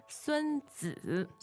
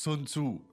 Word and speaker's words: 孙子: The pronunciation, you read that as 孙子. – 孙子, there you go. – 孙子. – 孙子. Good. So he's the guy who wrote The Art of War. Sometimes pronounced Sun Tzu by yes.